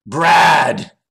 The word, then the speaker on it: Brad: Brad. – Brad. Rolls off the tongue. Brad. – No, you didn't pronounce it correctly. You have to, there's more emphasis on the D. Make it more harsh. Brad.